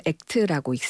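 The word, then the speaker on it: ACT: 수용 전염 치료는 Acceptance Commitment Therapy라고 해서 ACT이라고 쓰고 읽기로는 ACT라고 읽습니다.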